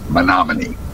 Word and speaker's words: Menominee: Jeff, it's Menominee.